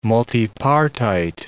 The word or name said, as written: multipartite